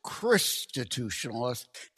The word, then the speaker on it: Christitutionalist: I hate to correct a host, but I pronounce it Christitutionalist, kind of like from the Greek Christos Mass, but it is indeed Christitutionalist, a term I invented and trademarked.